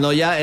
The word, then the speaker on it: Neuer: Manuel Noya era mi abuelo. Yo digo, ese se pronuncia, es que es Neuer. Pero se pronuncia casi igual, sí, sí. – Sí, sí, se pronuncia en Noya.